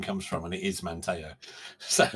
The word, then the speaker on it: Manteo: I forget the details now, but the correct pronunciation is Manteo.